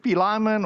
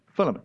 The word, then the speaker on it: Philemon: I know a lot of people, and their name is Philemon, not Philemon or any other strange pronunciation. Philemon.